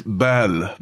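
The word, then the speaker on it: balle: No, it's belle. Ballon usually refers to like a big ball, but like if it's a small ball.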